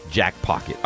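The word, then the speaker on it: Jackpocket: Last week you said I pronounced it incorrectly. This week I'm enunciating much more clearly. Jack Pocket. All right, let's go through these games chronologically.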